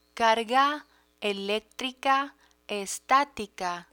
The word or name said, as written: carga electrica estatica